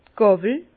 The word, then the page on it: d' Gàwel: Bas-Rhin; Herrlisheim